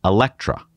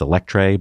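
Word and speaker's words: Eletre: the Electra. And I know it looks like it's Electre, but that's how they pronounce it.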